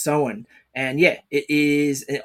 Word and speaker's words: Samhain: Now, funny thing I actually found out, I went reading about it, I kept reading it in my brain as Samhain as well, but it's actually pronounced Samhain because it's the old Gaelic word.